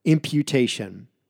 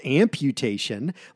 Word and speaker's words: imputation: I-M-P-U-T-A-T-I-O-N. Imputation. This word is not amputation, like cutting off an arm or a leg. It is imputation.